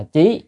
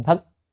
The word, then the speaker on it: thất: Biết chữ thất này có âm là trí, nhưng ở đây là phải là âm thất. Thất thì mới là biết, còn trí là ghi nhớ.